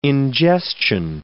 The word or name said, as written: ingestion